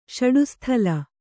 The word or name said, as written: ಷಡುಸ್ಥಲ